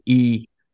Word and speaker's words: Yi: The Yi yes. series. – Did I pronounce that right? Y I, Yi. You're the official Chinese speaker Yi. on the show. It would be E.